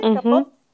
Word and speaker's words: Capote: Capote.